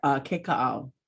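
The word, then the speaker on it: Keka'au (Kekaʻau): Keka'au.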